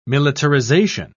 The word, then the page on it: militarisation: British English